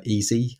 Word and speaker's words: Eze: You know, you could say the same about Ings, but the player that I like is Easy, or EZE as it's spelled, at QPR.